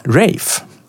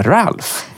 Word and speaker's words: Ralph: Det var ju lite otippat att han uttalade sitt förnamn, Rafe. Med tanke på att det finns fler som stavar exakt som honom, där vi alltid har kallat honom för Ralph.